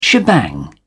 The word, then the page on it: shebang: British English